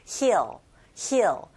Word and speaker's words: heal: 즉 치유하는 사람들, 즉 치유자를 가리키는데 heal 할 때 소리 내실 때 좀 길게 하셔야 되죠. heal, heal. 그런데 언덕이란 단어는 hill, hill. 짧게 발음한다는 거.